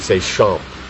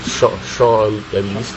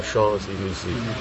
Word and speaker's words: Champs-Élysées: I mean, in English we'd say Champ, but it's Champs-Élysées. Yeah, Champs-Élysées.